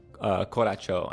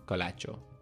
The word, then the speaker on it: Colacho: El Cachalo? – Coracho. I think I mispronounced it on the first read-through. – Can you spell it? Is that a translation of what they're jumping over? – C-O-L-A-C-H-O. Coracho.